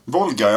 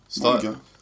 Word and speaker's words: Volga: V-O-L-G-A. – Vet du någon flod? – Folga. – Jo, det är det. Volga. – Ja, är det F? Uttalar man vet med F? – Nej, Volga. – Folga. – Volga som är Viktor. Man säger inte Viktor.